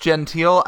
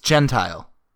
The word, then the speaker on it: Gentile: Wait, do you actually think it's pronounced genteel? I thought you were saying that as like a bit before. – No. – It's Gentile.